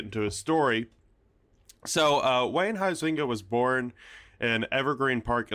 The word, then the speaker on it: Huizinga: How do you say it? – The way that I understand how to pronounce it now, Wayne... Huizinga. – You're full of shit. – So like Hawaii, like the company.